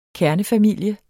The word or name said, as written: kernefamilie